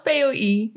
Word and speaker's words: POI: En, ehm, en ik, weet, ehm, ik weet dat jij een ervaring hebt met POI, zeg ik dat goed? POI? Hoe, hoe zeg je het zelf? Ja, ik zeg zelf POI.